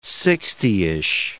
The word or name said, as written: sixtyish